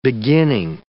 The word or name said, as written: beginning